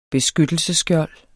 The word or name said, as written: beskyttelsesskjold